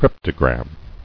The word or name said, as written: Cryptogram